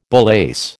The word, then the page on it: bullace: American English